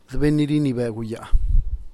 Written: dbennynirinibeguyaa

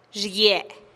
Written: xiguie

